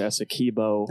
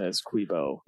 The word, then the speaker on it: Essequibo: And so he really attempted to create a good faith arbitration on this border dispute over the issue of Essequibo. Is that right? How to pronounce it? Essequibo? – Well, it depends who you ask. Venezuelans would probably say Essequibo. – Essequibo. – Guyanese would say Essequibo.